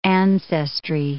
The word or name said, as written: ancestry